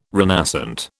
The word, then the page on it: renascent: British English